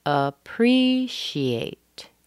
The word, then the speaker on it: appreciate: Appreciate. Appreciate. Now, we start with a schwa. The next word, the next syllable, is a primary stress.